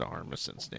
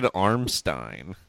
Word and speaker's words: Armisen: Well, I guess that makes sense because he also refused to pronounce Fred Armisen's name correctly for two seasons. – Fred Armisen! – He kept calling him Fred Armstein.